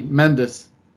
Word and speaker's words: Mendez: The accent is on the first E, Mendez.